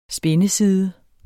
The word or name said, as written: spindeside